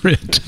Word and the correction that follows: REIT: you need to, it's pronounced REIT. Okay.